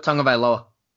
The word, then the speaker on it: Tagovailoa: I even love Trevor Lawrence in, Clemson, Tua Tagovailoa. I think I pronounced that correctly. – Tua Tagovailoa. – Close enough. And I love my boy over in LSU, whose name is killing me right now.